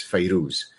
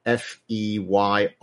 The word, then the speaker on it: Fairuz: Her name is pronounced in different ways, but probably the most common way, one is Feyrouz or Fayrouz, Fairuz.